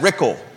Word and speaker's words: Rickle: she's running against Brad Rickle, rhymes with pickle.